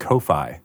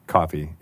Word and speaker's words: Ko-fi: I never realized, obviously I've seen people buying coffees for people on that platform, and I always just assumed it was pronounced Ko-fi. I don't know why. – I mean, it kind of is spelled that way. – Right? Yeah. It's one of those D&D problems where you see something written and you say it in your head and it's just how you pronounce it in your head. Of course you would buy a coffee on coffee, even though it's spelled K-O-F-I.